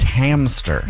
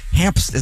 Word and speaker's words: hamster: It's not hamster, it's hamster. – Yeah. – Like a ham and stir. And I've been mispronouncing that my whole life. Hamster, there's no P in hamster.